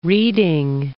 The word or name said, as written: reading